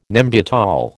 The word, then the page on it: Nembutal: American English